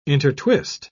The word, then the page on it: intertwist: British English